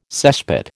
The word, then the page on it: cesspit: American English